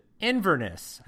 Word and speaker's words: Inverness: Inverness.